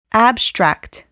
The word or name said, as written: abstract